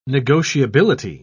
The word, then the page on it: negotiability: British English